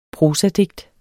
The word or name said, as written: prosadigt